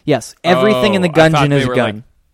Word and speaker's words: Gungeon: Anything else about Gungeon? Am I saying that right? Enter the Gungeon? – Enter the Gungeon. – Like a dungeon, but with guns.